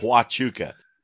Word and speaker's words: Huachuca: Huachua. No, Huachuca. Shit, I don't know what you said. – Huachuca. Between the Whetstone and Huachuca Mountains last Saturday by two ranchers who were returning home from the Huachucas.